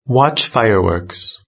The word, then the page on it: watch fireworks: British English